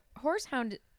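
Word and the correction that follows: horehound: Whorehound. No S. Whorehound.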